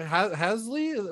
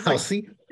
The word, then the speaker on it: Halsey: Halsey or what? Hasley? – Halsey.